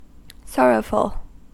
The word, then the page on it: sorrowful: American English